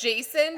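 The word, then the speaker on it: Jacen: It's Jason, but everyone it's spelled... – Everyone liked her. Jason and Dana. Awesome. – Oh, that's right, because it's spelled bad. – Yeah, it's J-A-C-E-N.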